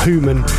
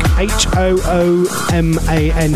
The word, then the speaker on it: Human: That's how you say it in Norfolk. Human. Human Traffic. H-O-O-M-A-N.